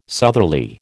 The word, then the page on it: southerly: American English